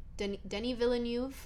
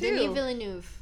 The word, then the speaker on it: Denis Villeneuve: Denis Villeneuve. – Sure. – I'm so sorry if I didn't say that right, but I think I did because I heard him say it himself, so that's right.